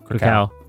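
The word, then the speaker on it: Kraków: How do you pronounce the place that it's at for PLG? Is like Krakow or something? – Krakow. – Yeah. Okay, there we go.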